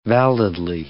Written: validly

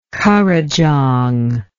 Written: currijong

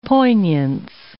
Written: poignance

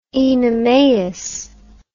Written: Oenomaus

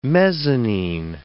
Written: mezzanine

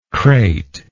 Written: crateful

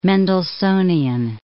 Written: Mendelssohnian

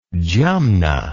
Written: jumna

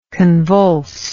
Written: convulsibility

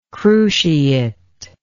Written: cruciate

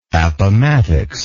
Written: appomattox

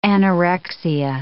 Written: anorexia

